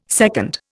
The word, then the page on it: secund: Indian